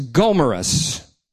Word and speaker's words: Gomarus: Their names were Theodore Beza, B-E-Z-A, and Franciscus Gomarus, Shazam, G-O-M-A-R-U-S.